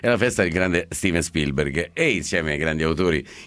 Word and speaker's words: Spielberg: Spielberg, la pronuncia è perfetta, si può rifare.